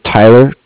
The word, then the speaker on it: Tyler: My name is Tyler, T-Y-L-E-R, and I'm calling with Cardiff. It's spelled C-A-R-D-I-F-F, as in Frank.